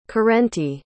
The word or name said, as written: corrente